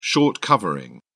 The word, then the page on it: short covering: British English